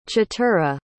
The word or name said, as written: chatura